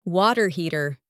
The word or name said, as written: water heater